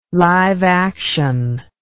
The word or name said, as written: live action